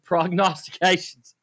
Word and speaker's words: prognostications: No, it's called Dougal's prognostications. – All right.